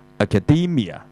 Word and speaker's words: academia: 佢咧個 e 字又轉咗發音啦。就係讀 academia，係啦，由 academic 就變咗做 academia，係啦。